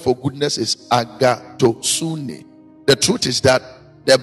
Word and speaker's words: agathosune: The Greek word for goodness is agathosune. A-G-A-T-H-O-S-U-N-E. Greek in India. Agathosune. The pronunciation is different from how it is spelled. A-G-A-T-H-O-S-U-N-E.